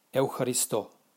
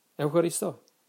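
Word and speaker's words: Eucharisto (ευχαριστώ): Even in modern Greek, if you guys were to go to Greece and somebody did you a favor, you know how you say thank you? You say Eucharisto. That's how they say it. Okay? So in Greece, if you want to say thank you, you say Eucharist. Eucharisto.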